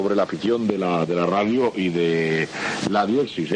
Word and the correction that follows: diexismo: ¿eh? Se dice así. – Diexismo. – Mejor dicho.